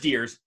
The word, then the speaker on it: DearS: I'm going to say Deers. Deers? – It's just Deers, yeah. – Deers? – But okay. It's spelled with the deer with a big S, yeah.